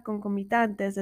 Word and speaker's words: concomitantes: Esta palabra concomitantes es una palabra llana, no se acentúa en la penúltima sílaba debido a que termina en S.